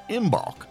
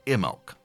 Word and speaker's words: Imbolc: Now, you may be familiar with this end of winter festival, and maybe you've seen it written as if it was going to be pronounced Imbolc, but I'm going to go by the three internet videos I watched about the proper Celtic pronunciation and say Imbolc.